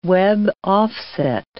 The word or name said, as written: web-offset